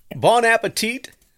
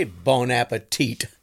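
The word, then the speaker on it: bon appétit: Apparently, you're not supposed to say bon appetit. It's bon appetit. There's no T at the end of it. – Oh, the last T is silent? – Last T is silent.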